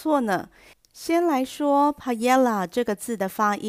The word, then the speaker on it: paella: 那对于会考生来讲，就以出版社所提供的音档发音 paella 为主。